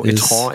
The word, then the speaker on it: e-tron: Ich finde es schön, dass du e-tron extra französisch ausgesprochen hast. – Ja, es ist ein französisches Wort, das sich Audi da ausgesucht hat, um ein bisschen Klasse zu zeigen. Und das muss man dann auch korrekt betonen.